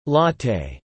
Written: latte

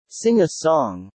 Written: sing a song

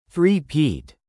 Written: three-peat

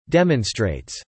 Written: demonstrates

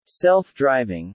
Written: self-driving

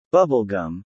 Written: bubblegum

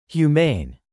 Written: humane

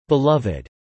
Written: beloved

